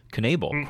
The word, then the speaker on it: Knebel: Knebel.